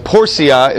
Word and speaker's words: lex Porcia: First one is the lex portia. Portia is the way we pronounce it. Porcia is the way they would have pronounced it in Latin because the C gets the S sound.